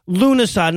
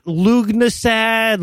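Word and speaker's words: Lughnasadh: And but it's pronounced Lunasa, not Lugnasad, Lufensad, Lugian salad, or any of the other gabillion ways I've heard people murder the word.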